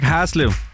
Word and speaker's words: Haslev: Udtalte jeg det rigtigt nok, Haslev? – Hej, Haslev. – Ja, ja, okay.